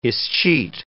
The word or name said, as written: escheat